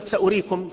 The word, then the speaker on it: سأريكم: وكلمة سأريكم في موضعيها تكتب سأريكم بواو بعد الهمزة، وهذه الواو زائدة، ويخطئ كثيرون أيضا مرات يقرؤوها سأريكم، يظنون أن هذه الواو يجب أن تلفظ ولا ينتبهون إلى علامة الزيادة التي عليها.